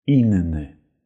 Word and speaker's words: inny: Inny. Und hier achten wir darauf, man schreibt dieses Wort mit 2 n deshalb spricht man auch 2 n Innene.